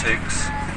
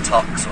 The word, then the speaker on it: Tukes: Because nobody could ever pronounce Tukes. Everybody saw it written down and said Tox or Tokes and things like that. – I have to admit, I was calling it Tokes. Because it is, what was it, T-O-C-Q-U-E-S? – T-O-Q-U-E-S, yeah.